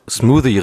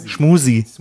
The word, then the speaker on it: smoothie: Ich finde, ihr solltet erstmal einfach Smoothie richtig aussprechen. Das wird mich schon freuen. – Schmoosie. – It's a smoothie. It is a smoothie. Now repeat after me. Smoothie.